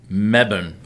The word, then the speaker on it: Melbourne: There's no L. – There's no... – Melbourne. – Mebane. – Melbourne. – Mebane. Oh, okay.